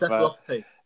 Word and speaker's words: encephalopathy: Encephalopathy, there you go, encephalopathy.